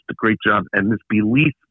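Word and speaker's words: Be-Leaf: There's one from the Herzog line that's called Belief. Like you say, believe. – Right. – All right. This one is spelled B-E. And then dash L-E-A-F.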